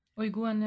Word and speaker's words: Uiguren: Aber wie gesagt, also wenn, wenn ich mich da um eingelesen habe mit den, ich kann das immer noch nicht richtig aussprechen, Uiguren? – Uiguren, ja.